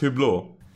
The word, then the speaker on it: Hublot: Speaking of watches, did you buy the Hublot watch? Or it's pronounced Hublot? Is that how it's pronounced? – It's pronounced Hublot.